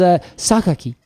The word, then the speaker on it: Sakaki: And I learned how to say this name. I looked it up on the internet, as I am wont to do. And it is Sakaki. – Yes. – And I love that. I had to remember that Japanese pronunciations are vastly different than pronunciations of Latin-based languages.